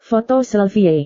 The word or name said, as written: foto selfie